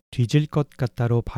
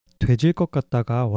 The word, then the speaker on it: 되질 것 같다: 마지막으로 한국인들 중에 되질 것 같다를 뒤질 것 같다로 발음하는 사람들이 많아요. 되질 것 같다가 원래 표준어이고 뒤질 것 같다는 사투리라고 보시면 되는데요.